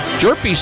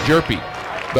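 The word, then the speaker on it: Hjerpe: Jerpy spells his name. Well, if you sound it out, it's J-E-R-P-E-E, Jerpy. But if you spell it, it's H-J-E-R-P-E. There's an H in there.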